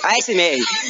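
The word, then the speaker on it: ASMR: porque é isso que a MV, não, não é MV, a SMR a SMR fazem, eu falei errado, mas é a SMR Sim,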